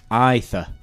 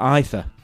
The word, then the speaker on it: Aether: Looking at it, it's Aether. You pronounce the A and the E. You actually... The letter is Ash. – It's what? Ash? – You know, like you've got G, H. That one's Ash. And then you you pronounce it as I. So it would be the Aether.